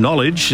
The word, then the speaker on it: knowledge: The silent letter in knowledge is the first one, K.